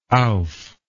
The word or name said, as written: ouphe